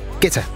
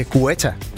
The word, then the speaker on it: Guetta: Dengang jeg arbejdede på Bruxelles Radio, der sendte han engang en fax om, at hans navn udtales Guetta. – Guetta. – Og ikke Guetta, som alle os andre havde grund at kalde ham. – Hvis det er fransk, så er det Guetta.